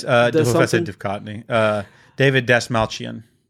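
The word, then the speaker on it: David Dastmalchian: Yes, David Dashmalchian.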